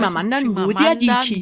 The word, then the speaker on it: Chimamanda Ngozi Adichie: Chimamanda Ngozi Adichie.